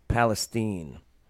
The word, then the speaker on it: Palestine: I believe it's pronounced Palestine, Ohio.